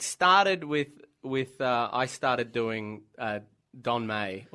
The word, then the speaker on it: Meij: So I started doing Don May, as we now know it is pronounced, Don May.